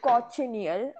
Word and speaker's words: cochineal: So these bugs are native to North America, where they live on cacti, and they are called cochineal. I don't like the pronunciation, but it seems that's just how it's pronounced. It's C-O-C-H-I-N-E-A-L.